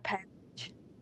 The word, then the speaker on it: Pavlich: But welcome, John Pavlich. – John Pavlich. – Yes. Rhymes with sandwich.